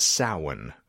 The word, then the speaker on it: Samhain: I mean, technically, but it's pronounced Samhain for the good folks that are actually Irish.